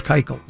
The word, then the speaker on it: Keuchel: I'm taking Dallas Keuchel.